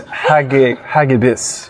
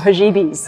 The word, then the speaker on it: Hagibis: Yeah, Gregor Townsend thought it was Typhoon Haggis, but I believe it is Hagibis. – Hagibis. – Yes. – Or Hajibis. – Hajibis, however you want to pronounce it.